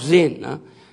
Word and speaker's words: Zin: Is that how you pronounce it? Yeah. Of Zin.